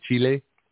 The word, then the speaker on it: Chile: I hope I pronounced it right. Chile. That's how I pronounce it? – Mm-hmm.